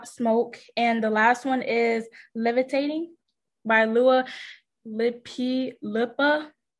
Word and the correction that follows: Dua Lipa: Dua Lipa. – This is so weird. But anyways, yeah. Dua Lipa. Yeah, Lua Deep Lipa. Lua Lipa. Dua Lipa. Am I saying that right? I don't know. Yes, ma'am. Dua Lipa.